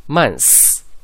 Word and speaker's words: months: 所以呢直接略掉咬舌音 s 发成 s 就可以了，所以这个单词呢读成 months Months。